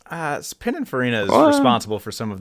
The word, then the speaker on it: Pininfarina: Pininfarina.